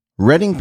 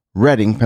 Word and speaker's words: Reading: Big thanks to Kayleen Stunts and my UK correspondent, Kevin Curtis Allen, for clarifying that R-E-A-D-I-N-G, Pennsylvania, Reading, Pennsylvania, is indeed pronounced Reading, Pennsylvania.